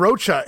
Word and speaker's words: Rocha: Had to get his name in one more time there. And I finally said it right. Andre and Rocha in Providence, Rhode Island.